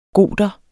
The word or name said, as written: goter